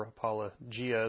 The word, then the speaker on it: apologia: I made a point every time to say why this is important, because as we understand the Greek word apologia, or apologia is how it's generally pronounced, apologetics, is giving an answer for our faith and our reasons why we believe what we believe.